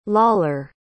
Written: lawler

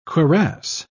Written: cuirass

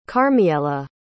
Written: karmiela

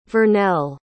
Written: vernell

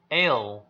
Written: ale

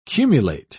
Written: cumulate

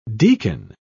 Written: deacon

